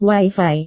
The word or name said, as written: wifi